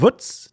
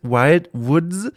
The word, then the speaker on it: Wild Woods: Ich muss immer ein bisschen lachen, weil ich das so ausspreche mit meiner süddeutsch-fränkischen Art wie die Woods, das kleine Schweinchen. Aber es heißt Wild Woods, also die wilden Wälder.